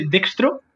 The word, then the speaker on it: Dextro: sí, yo, mi, nombre de usuario en toda red social es siempre el mismo, es José Dextro, siendo D-E-X-T-R-O. Sé que es difícil de pronunciar, pero es de Dextro, en todos lados.